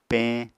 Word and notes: peñ: Apatani

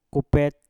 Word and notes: kupet: Mpur

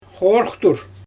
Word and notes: xoorxtur: Xyzyl

